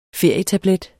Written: ferietablet

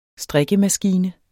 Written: strikkemaskine

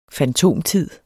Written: fantomtid

